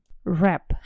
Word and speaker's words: wrap: Wrap. How do you spell that? It's W-R-A-P. W-R-A-P. И вот буковка W не произносится.